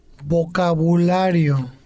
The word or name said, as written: vocabulario